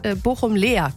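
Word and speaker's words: Bochum-Laer: Bochum-Lea gibt's ja auch, wird mit AE geschrieben und mit Ä ausgesprochen. Nicht wie bei Strahlen am Niederrhein, dass er mit A ausgesprochen wird.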